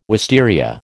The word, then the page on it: wisteria: American English